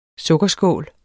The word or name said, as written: sukkerskål